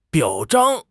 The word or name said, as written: 表彰